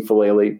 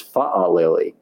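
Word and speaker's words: Faalele: I've been using Falele, but I am told it is Fa'alele, even though I don't believe he has the apostrophe in between the two A's at the beginning of his name.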